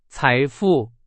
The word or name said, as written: 财富